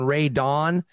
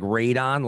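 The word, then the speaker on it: Rae Dawn: And I know you were hating on Ray Dawn, which everybody is like, pronounces like Radon, like I just had my best basement tested for.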